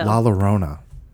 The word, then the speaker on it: La Llorona: La Llorona. – Sounds good. – Is that like My Sharona? – Yeah, just like it.